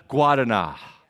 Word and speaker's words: Guaraná: and you're going to get messed up because it's spelled G-U-A-R, but you pronounce Guaraná.